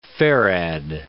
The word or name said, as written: farad